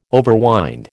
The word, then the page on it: overwind: American English